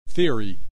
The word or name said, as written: theory